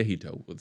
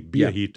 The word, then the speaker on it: Biahito: Piahito? Is that right? – Yeah, Biahito, sort of pronounced with a B. – Oh, with a B, Biahito.